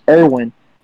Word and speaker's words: Irwin: My name's Irwin. That rhymes with Irvin.